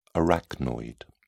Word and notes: arachnoid: British English

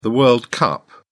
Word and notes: world cup: British English

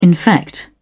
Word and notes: infect: British English

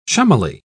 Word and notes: chummily: British English

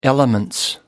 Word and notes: elements: British English

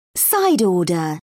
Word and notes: side order: British English

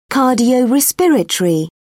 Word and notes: cardiorespiratory: British English

